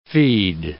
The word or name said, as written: feed